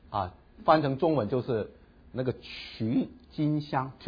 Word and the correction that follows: 郁金香: TULIP 啊，郁金香啊，郁金香啊 OK，幸好改正一下这个发音。